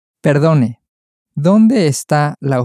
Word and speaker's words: está: So, the is or it is that we've been using is pronounced está. – Está. – Está. – Está. – And the emphasis is really very much on the sta at the end.